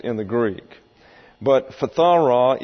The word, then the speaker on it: phthora: That's P-H-T-H-O-R-A, phthora. And usually in English, we accent the second syllable going back from the end. So phthora would be a lot easier for us to say.